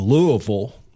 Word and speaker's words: Louisville: Born in Louisville. That's Ooh. How you say it. – Louisville, that's right.